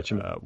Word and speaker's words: Cimino: Is that Cimino? Am I pronouncing Cimino. – That? – Yeah. – Yeah, Cimino.